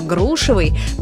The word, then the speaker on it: грушевый: Но вернемся к грушам и запомним, что ударение во всех формах прилагательного «грушевый» падает на первый слог, как в слове «груша».